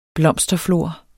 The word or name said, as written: blomsterflor